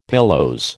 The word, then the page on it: pilose: American English